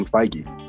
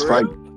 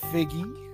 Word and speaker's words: Feige: I said Kevin Feige. – Okay, I never knew it was pronounced like that. – Yeah, it's Feige. It's how it's pronounced. It's Kevin Feige.